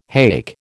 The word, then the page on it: hake: American English